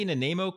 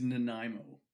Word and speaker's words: Nanaimo: Mr. Did I say Nanaimo correct? – Almost. You're very close. – Oh, how do you say it? – It is Nanaimo. – Nanaimo.